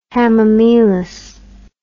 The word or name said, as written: hamamelis